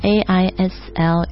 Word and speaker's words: aisle: 那这个词大家注意一下哦， aisle，这个 s 是不发音的，不是 aisle， so 是 aisle， walk down the aisle。